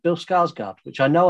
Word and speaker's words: Skarsgård: But it's the correct pronunciation is Skarsgård.